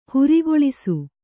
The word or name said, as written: ಹೊರೆಗೊಳಿಸು